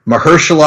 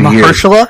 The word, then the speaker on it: Mahershala: I just learned this weekend, that we've been pronouncing it wrong on this show for the better part of two Mahershala? – Years. Mahershala? That's how he said it in the presser.